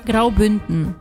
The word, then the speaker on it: Graubünden: Graubünden. Falei certo? – Graubünden.